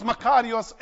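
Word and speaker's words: Makarios (μακάριος): The Greek word is Makarios, M-A-K-A-R-I-O-S.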